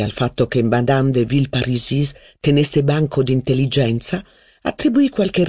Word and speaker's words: Villeparisis: Il discorso in cortile era caduto appunto su Madame de Villeparisis. Mi ha detto che è sua zia. Lui pronuncia Viparisi.